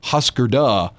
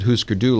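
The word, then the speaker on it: Hüsker Dü: So it would be pronounced, I guess, Husker Du. – Maybe. – If you did it correctly, but they pronounce it Husker Du like the original game.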